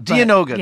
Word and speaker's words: Dianoga: Is it Diagona? Is that how you pronounce it? – I pronounce it Dianoga. – Dianoga. Yeah. That sounds better.